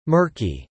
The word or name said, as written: murky